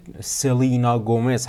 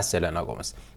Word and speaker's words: Selena Gomez: بگید که خواننده مورد علاقه روز سلینا گومز هست و تلفظ اسم این خواننده سلینا گومز هست، نه سلینا گومز.